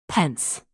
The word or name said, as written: pence